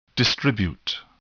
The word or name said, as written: distribute